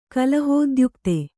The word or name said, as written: ಕಲಹೋದ್ಯುಕ್ತೆ